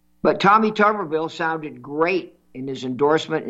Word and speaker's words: Tuberville: Tommy Tuberville, I think he pronounces his name Tuberville. Everybody says Tuberville, but I think I heard him on Fox yesterday thanking, was it Steve Ducey, for pronouncing his name. He said, thank you for pronouncing my name correctly. It's Tuberville. You're the only one who's done it.